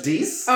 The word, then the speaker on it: Deuce: It's pronounced Deuce. – Oh, I'm sorry, I have an accent. I'm from a different area than you. – Did you say Deuce or douche?